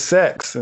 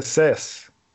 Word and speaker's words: seis: No, if you pronounce it sex instead of cess, somebody can take that anime a whole nother direction.